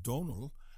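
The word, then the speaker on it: Domhnall: I like him, but his his name looks like Domenhall and that's Celtic. It looks it's pronounced Donal, and it looks like Domenhall.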